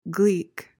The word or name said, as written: gleek